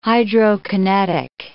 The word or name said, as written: hydrokinetic